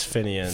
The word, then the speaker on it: Finian: It's not spelled like that though, but yeah, everyone thinks my real name's like Finley or Phineas, but it's Finian.